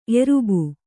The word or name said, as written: ಎರ್ಬು